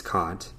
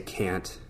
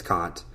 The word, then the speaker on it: Kant: Anyways, if any of y'all were curious about who Immanuel Kant is and how to say his name, it's Kant. A lot of people say Kant. That's fine. But it's Kant.